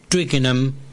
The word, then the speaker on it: Twickenham: En el caso de Tottenham y Twickenham como terminan en enam es clavado que no se pronuncia, no se aspira la H.